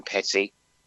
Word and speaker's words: Petty: For myself, Martin Petty.